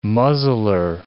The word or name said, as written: muzzler